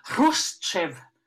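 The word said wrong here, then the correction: Chruschtschow: Khrushchev, ja, ja.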